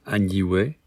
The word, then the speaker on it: Añihue: We are on the island of Añihue, if I've said that right. – Exactly.